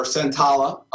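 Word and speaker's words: Santolla: Ralph Santola, or Santala, it's actually pronounced properly, from Obituary, Deicide, Death, Fame, Millennium, is playing second guitar and playing guitar.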